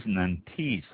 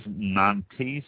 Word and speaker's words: Nantes: The first one we're going to talk about is the Pays Nantes, I believe it's pronounced, N-A-N-T-E-S, and as I always do, I will apologize for my French.